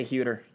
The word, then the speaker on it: Hueter: Okay, H-U-E-T-E-R. – Okay. – Hueter? What do you think? – I'd say Hueter. – Hueter? Yeah. Bob Hueter. That's a good. – Because H-U-E is usually Hue.